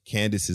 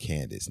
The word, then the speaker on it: Candiace: It's C-A-N-D-I-A-C-E. But you pronounce it Candace, not Candiace or whatever. – Oh. – It's just Candace.